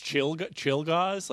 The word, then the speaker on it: Czolgosz: So then basically her next little bit of public life is this weird guy, Leon Czolgosz. Chilgoss? – I heard it pronounced Chilgoss, like chill gossip.